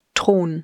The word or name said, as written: Thron